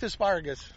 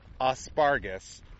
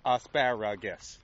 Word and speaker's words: asparagus: We're having a great year with asparagus. – And as we've mentioned many times, is the only farmer we know that calls it asparagus, not with four syllables, asparagus. Three syllables, asparagus.